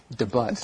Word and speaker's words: debut: But it's pronounced debut. – Debut, like the cat has debut. – Right. – Now, okay, so anyway, yeah, you're right. The T on the end of that word, you don't say the T, right?